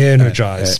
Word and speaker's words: Airnegize: Is that how you pronounce it? Yep. Energize. – Spelled Energize. – A-I-R-N-E-G-I-Z-E Capital.